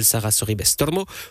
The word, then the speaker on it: Sara Sorribes Tormo: Il s'agira de l'Espagnole Sara Sorribes Tormo ou l'Américaine Jessica Pegula.